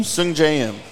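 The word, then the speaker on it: Sungjae Im: What'd you call him again? – Sung Jae-im. – You're getting better at pronunciation. That's exactly how to say it.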